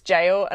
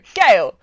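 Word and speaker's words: gaol: I can never pronounce this word. I know it's jail, and I always go gale.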